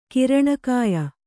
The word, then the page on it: ಕಿರಣಕಾಯ: Kannada; kiraṇa kāya